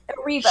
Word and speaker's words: Erivo: And how did Cynthia, how did you say her last name, Kristen Erivo? – Erivo.